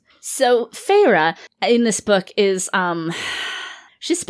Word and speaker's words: Feyre: So Farah is our lead. – Feyre.